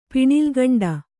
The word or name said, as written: ಪಿಣಿಲ್ಗಂಡ